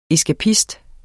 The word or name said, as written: ESKAPISTERNE